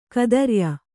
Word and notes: ಗೋತ್ರಯ: gō traya

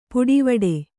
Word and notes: ಪುಡಿವಡೆ: puḍi vaḍe

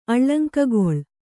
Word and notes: ಅಳಂಕೆಗೊಳ್: aḷaŋkegoḷ

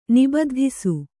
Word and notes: ನಿಬದ್ಧಿಸು: Kannada

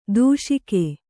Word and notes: ದೂಷಿಕೆ: Kannada

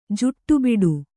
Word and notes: ಜಾಡಬೂಡ: jāḍa būḍa

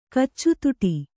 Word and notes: ಕಚ್ಚುತುಟಿ: Kannada

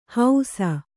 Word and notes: ಹೌಸ: hausa

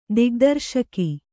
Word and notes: ದಿಗ್ದರ್ಶಕ: Kannada; digdarśaka

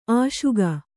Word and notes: ಅಶೋಕ: aśoka

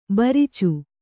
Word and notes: ಭರ್ಚಿ: Kannada

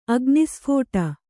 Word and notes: ಅಗ್ನಿಸ್ಫೋಟ: Kannada; agnisphōṭa

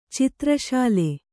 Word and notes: ಚಿತ್ರಶಾಲೆ: citra śale

